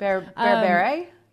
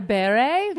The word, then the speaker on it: berbere: Like you said, you know, I started adding more, I never know how do you pronounce it. Berbere? Did I say it right? – Berbere? Yeah. – Berbere, yes. And it's like you use it once,